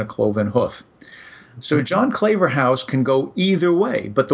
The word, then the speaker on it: Claverhouse: And I looked at the word Claverhouse. And the reason I'm pronouncing it that way is because clave is the past tense of the verb cleave.